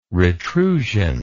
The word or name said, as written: retrusion